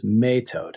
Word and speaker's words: metod: But an example of one that doesn't overlap, the term for God that isn't used for kings, is metod. It's M-E-T-O-D, or sometimes it's spelled M-E-O-T-O-D, depending on which dialect of Old English. And it's related to the word mete out, like you mete out a punishment today.